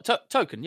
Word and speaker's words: Tolkien: Yeah, I'm going to go with the Rings trilogy, because as much as people may love Tolkien, sorry, did I say that right? – What, Tolkien? Yeah, absolutely. Yeah, you said that right.